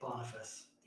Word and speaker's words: Boniface: Boniface.